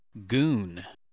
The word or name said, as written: go on